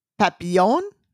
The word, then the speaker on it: Papillon: P-A-P-I-L-L-O-N. And the reason I'm spelling it is because I would like to know how you pronounce that dog. – Papillon? – Papillon. – Is that right? – It's the only way that Merriam-Webster has it said.